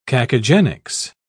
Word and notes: cacogenics: British English

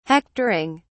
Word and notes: hectoring: British English

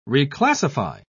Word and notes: reclassify: British English

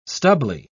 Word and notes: stubbly: British English

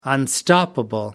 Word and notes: unstoppable: American English

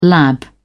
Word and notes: lab: British English

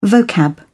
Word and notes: vocab: British English

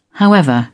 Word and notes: however: British English

